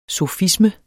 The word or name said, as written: sofisme